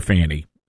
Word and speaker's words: Fani: She goes on in an interview to say that her first name is Swahili, meaning I guess you pronounce it Fani. We're going to call her Fanny. That I think that's the Americanized pronunciation.